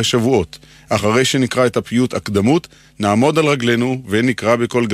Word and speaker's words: הקדמות: אמרנו שהפיוט נקרא הקדמות, על שם מילת הפתיחה שלו, אבל כבר שמעתי מבטאים זאת הקדמות, כביכול צורת ריבוי. חלק מהטעות קשור לשיטת היידיש, שהרי היידיש הדגישה לא את העברה האחרונה, אלא את שלפני האחרונה. לא הקדמות, אלא הקדומס. מנסיבות שההברה האחרונה לא הודגשה, התעמם הצליל המדויק שהיה בה. במקום צרות אמרו צורס, במקום בהמות בהמס, ולהבדיל במקום הקדמות אמרו הקדומס.